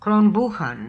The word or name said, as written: Kronbookhon